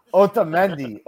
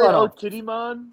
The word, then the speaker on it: Otamendi: Otamendi. – Did he say Otidimon? – I said Otidimon.